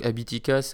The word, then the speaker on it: Habitica: Donc Abitica, euh, ça s'écrit H-A-B-I-T-I-C-A.